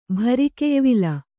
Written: ಭರಿಕೆಯ್ವಿಲ

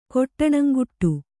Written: ಕೊಟ್ಟಣಂಗುಟ್ಟು

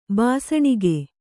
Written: ಭಸಣಿಗೆ

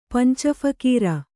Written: ಪಂಚಫಕೀರ

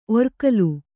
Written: ಒರ್ಕಲು

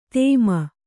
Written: ಧೂಮ

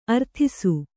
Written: ಅರದೇಸಿ

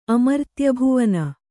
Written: ಅಮರ್ತ್ಯಭುವನ

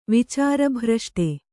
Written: ವಿಚಾರಭ್ರಷ್ಟ

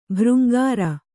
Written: ಭೃಂಗಾರಿ

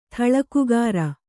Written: ಥಳಕುಗಾರ

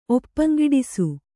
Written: ಒಪ್ಪಂಗೆಡಿಸು

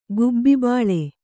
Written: ಗುಬ್ಬಿಬಾಳೆ

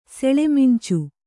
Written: ಸೆಳೆಮಂಚ

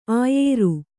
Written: ಆಯಾರ

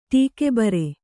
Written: ಟೀಕೆಬರೆ

